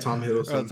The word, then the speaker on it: Hiddleston: Yeah, I feel like it's going to be one-off just because Hiddleston. Hopefully I'm pronouncing that correctly. Hiddleston? Right, yeah. Right. Loki. I forget. That's his real name, right? – Yeah, Tom Hiddleston.